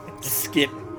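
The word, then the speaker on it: Schiit: So it's S-C-H-I-I-T. – Okay. – Skitta. I forget how, like, the real pronounce. I think it's pronounced like Skitta.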